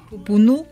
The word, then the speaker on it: Pupunú: Pupunú con acento en la U,